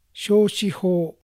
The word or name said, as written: Shoshiho